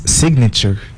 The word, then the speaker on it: signature: The first one we have like signature. – Oh, actually it's signature. – Signature. So yeah. the correct pronounce is signature.